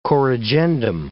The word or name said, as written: corrigendum